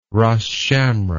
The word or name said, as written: Ras Shamra